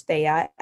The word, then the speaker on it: Feiya: So my profile or my username is Jessica, and my middle name, which is Feiya, F-E-I-Y-A, and my last name, which is spelled L-I.